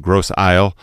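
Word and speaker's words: Grosse Île: Located in the middle of the St. Lawrence River, Gros Eel, or sometimes it's pronounced Gros Isle, was Canada's main immigration gateway, and it served as a quarantine station for the port of Quebec City from 1832 to 1937.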